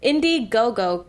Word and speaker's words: Indiegogo: Indiegogo campaign. I hope that you said that correctly.